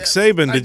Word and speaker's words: Saban: Nick Saban.